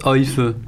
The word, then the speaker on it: Aoife: Parce que qu'on vient d'écouter Oife. Alors, ça s'écrit A-O-I-F-E. Ouais. Ça se prononce Oife.